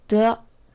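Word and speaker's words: 더 (的): 두 번째 글자는 더입니다. 더 D-E 더 이건 경성이라고 해서 특별히 높낮이가 정해진 음은 아닙니다. 앞 글자에 발음되는 성조에 따라서 음, 적당히 올라오거나 떨어지는 음이에요.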